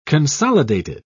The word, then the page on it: consolidated: British English